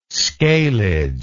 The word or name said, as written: scalage